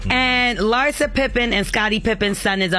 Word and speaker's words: Larsa Pippen: Larsa Pippen.